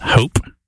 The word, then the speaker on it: Haupe: That's, how would you say that? Hope? – I think so. – H-A-U-P-E. And like, then, you say taupe. – Taupe, hope.